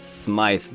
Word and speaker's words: Smythe: It's spelled so close to Smithers. I keep wanting to say Smithers when I read his name. It's Smythe, though. S-M-Y-T-H-E.